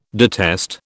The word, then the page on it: detest: British English